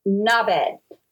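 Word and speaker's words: knobhead: Knob head, but they don't pronounce the H. So it's like, Nubbed.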